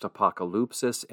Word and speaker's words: apokalypsis: In the Greek, it is pronounced apokalypsis, and it carries a very, very powerful background in a history to it that helps us understand overall.